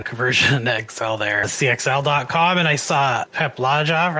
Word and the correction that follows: Laja: I think it's Laha is how you pronounce his last name. – Yeah, Laha.